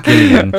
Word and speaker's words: Gillian: It stars, and I'm not pronouncing this incorrectly, Gillian Jacobs. She is Gillian,